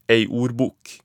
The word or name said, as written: ei ordbok